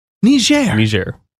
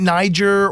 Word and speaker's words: Niger: go ahead and pronounce it. – Niger. – And I thought, here I am, you know, been calling it Niger or, you know, however I referred to the name of that country.